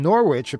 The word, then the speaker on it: Norwich: And Norwich, apparently, is pronounced Norwich.